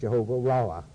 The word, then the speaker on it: Jehovah Raah: Jehovah R-A-A-H, which is pronounced Jehovah Raah.